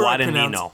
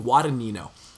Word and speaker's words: Guadagnino: And then as far as director at the Globes, I mean, Scott was probably a fluke, but Guagadino, how, how, how do I pronounce it? Is, is it Guagadino? – however Guadagnino. I Guadagnino.